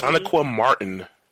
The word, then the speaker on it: Sonequa Martin: Sonequa Martin.